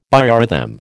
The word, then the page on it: biorhythm: American English